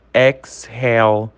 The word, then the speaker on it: exhale: Mas existem algumas exceções, como a palavra exhale. Exhale, o H, ele tem um som aí, hell, né?